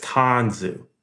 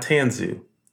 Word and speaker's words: Tanzu: And it's Tanzu, not Tanzu, Tanzu.